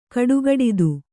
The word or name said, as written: ಕಡುಗಡಿದು